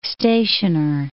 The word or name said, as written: stationer